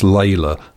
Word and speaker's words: Leila: Spelt L-E-I-L-A, but pronounced Layla.